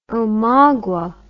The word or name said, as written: omagua